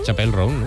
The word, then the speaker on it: Chappell Roan: Chappell Roan, ¿no? – Ella misma nos explica cómo se pronuncia. – Chappell Roan. Ah, pues lo digo mal yo. – Chappell Roan. – Apple, Apple.